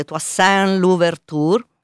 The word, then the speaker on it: Toussaint Louverture: Tra l'altro, ecco, ricordo che una figura di spicco durante la rivolta fu uno schiavo liberato, adesso vediamo se dico bene in francese, Toussaint Louverture. – Che brava. – La mia pronuncia è terrificante.